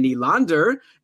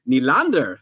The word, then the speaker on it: Nylander: and then Hyman with Marner and Nylander. And by the way, that's another pronunciation I got corrected on yesterday. It's William Nylander, not Nylander.